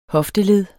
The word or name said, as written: hofteled